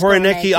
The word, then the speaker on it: Koroneiki: They actually told me when I come to Greece, I must try the Koroneiki. Am I pronouncing that? Yes. Koroneiki olive oh, because they yes.